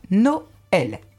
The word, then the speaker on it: Noël: Son rôle, indiquer la diérèse, c'est-à-dire la prononciation en deux syllabes, Noël. Sinon, on ne ferait pas Noël, on ferait Noël. Voilà. Ah oui, Noël. – Comme les pneus.